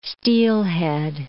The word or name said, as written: steelhead